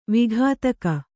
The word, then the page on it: ವಿಘಾತಕ: vighātaka